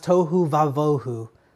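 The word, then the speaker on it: tohu vavohu (תֹהוּ וָבֹהוּ): You see, what the English translation misses is that the Hebrew words are tohu and bohu, often pronounced tohu vavohu.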